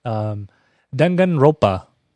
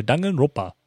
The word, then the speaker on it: Danganronpa: There's an extra N in this that you don't hear. Danganronpa. There's I think because it's spelled Ronpa, but I only hear Danganronpa when I listen to the game. So I'm going with that.